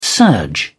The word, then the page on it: surge: British English